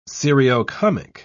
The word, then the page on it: seriocomic: British English